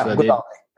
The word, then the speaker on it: Godhawale: Yeah, Godhawale.